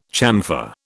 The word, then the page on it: chamfer: British English